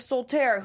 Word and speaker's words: Soltaire: How do you say his name? Because did you read that part yet? – Soltaire. – Because I spelled it Soft-tail.